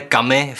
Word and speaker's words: Camus: Eh, Hned hnedka na začátek ujasním, hledal jsem si to a skutečně se to vyslovuje kami v prvním pádě, ale kamise ve druhém pádě.